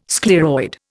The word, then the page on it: scleroid: Indian